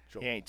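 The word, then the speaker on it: Joeckel: You said Lockle. – Did I say Lockle? – It was like Locker. – With Jake Locker. – Yeah. Jokel. He ain't Jake Locker.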